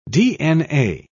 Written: dna

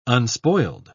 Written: unspoiled

